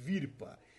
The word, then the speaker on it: Vhirpa: Esse Virpa se escreve com Vhirpa, Virpa.